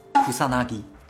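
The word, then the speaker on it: 草薙: 怎么读啊？草剃？ – 库萨纳基。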